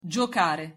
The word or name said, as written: giocare